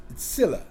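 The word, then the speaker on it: Tzillah: The name of one was Ada, Linda, and the name of the second was Tzilla. It's, you pronounce it with a T in front of it.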